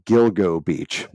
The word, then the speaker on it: Gilgo Beach: Originally, the case began in 2010 when they found four female bodies on a desolate stretch of Ocean Parkway near Gigolo, or I call it called Gigolo Beach. It's Gilgo Beach, by the way. Just a gigolo. Sorry, Gilgo Beach. I don't know why I keep mispronouncing that.